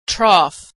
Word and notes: trough: gh

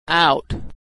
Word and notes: out: aw

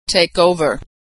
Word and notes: take over: verb

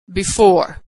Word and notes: before: female

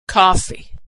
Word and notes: coffee: female

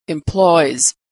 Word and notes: employs: z

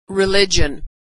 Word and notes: religion: dzh

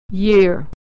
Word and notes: year: /y/

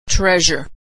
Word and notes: treasure: zh

